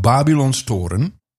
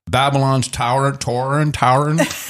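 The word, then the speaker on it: Babylonstoren: The vineyards of Babelonstoren, I'm pronouncing it properly. – Okay. – The people in America would say Babylon's Tauren, are nestled on the slopes of Simonsberg Mountain, just north of Stellenbosch.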